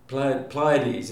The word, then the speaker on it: Pleiades: Pleiades is how it can be pronounced.